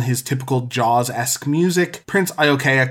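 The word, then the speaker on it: Iaukea: Next, we get the second appearance of Prince, I have to say it right, Iokea. I think I got it right this time. – Yeah, you got it.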